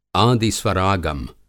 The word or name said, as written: आदि स्वरागम